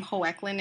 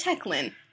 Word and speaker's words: Hoechlin: And I'm sorry that we pronounced his name Hoechlin in the Teen Wolf fandom episode, but, like, we literally had no idea how to pronounce that word. It's Hoechlin, because he is heckling us.